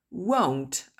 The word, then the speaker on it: won't: Will not. Ou won't. Attention, la prononciation est différente. Vous risquez de ne pas le reconnaître. Donc, bien l'apprendre à le prononcer.